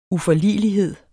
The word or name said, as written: uforligelighed